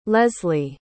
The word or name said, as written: lesley